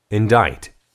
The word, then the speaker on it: indict: indict. indict. – 기소하다, 고발하다, 비난하다. 읽을 때 조심해야 될 것은 C가 묵음입니다. indict라고 읽으면 안 되고 indict. – indict. – indict. – indict라고 읽어요.